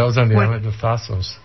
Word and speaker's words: Thassos: Thassos, which rhymes with Yassos.